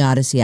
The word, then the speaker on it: Audacy: All you need to do to win $5,000 every day is listen to us on the Odyssey app. The Odyssey app is free. It's spelled funny. A-U-D-A-C-Y.